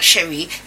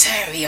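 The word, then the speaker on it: Ceri: No, Jenny, it's not Cherie. It's Sherry or something like that.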